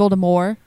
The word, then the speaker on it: Voldemort: She never wanted the T pronounced. We learned that when we were doing our Harry Potter Star Wars podcast. Uh-huh. It was always supposed to be Voldemort, like Voldemort. Yeah. And then people said T.